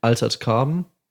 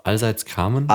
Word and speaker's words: Altered Carbon: Weiß nicht, ob ihr, hat jemand von euch Altered Carbon gesehen? – Hm? – Altered Carbon? – Nee. – Das ist auch Allseits Carbon? – Altered Carbon.